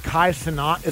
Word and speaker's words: Kai Cenat: I'll see these little clips of, like, there's a guy named Kai Cenat.